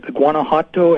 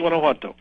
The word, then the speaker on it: Guanajuato: Now, you acquired your two mines, forgive me if I butcher the pronunciation, but Guanajuato and Guanacaví. – Hey, that's very good. – Okay. – Guanajuato.